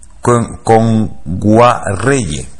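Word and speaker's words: Kngwarreye: Emily Kame Nguayere, y lo he dicho mal. – Emily Kame. – Con Guarreye. – Emily Kame con Guarreye, sí, sí.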